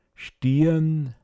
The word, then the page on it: stian: Pinzgauer Mundart